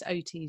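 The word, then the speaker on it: Oattes: I think it's OT as you pronounce his surname.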